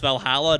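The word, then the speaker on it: VA-11 Hall-A: I actually got to talk to one of the half developer, half publishers for that game, and found out I was pronouncing that game's name completely wrong. It's pronounced Valhalla, not the numbers I thought it was initially.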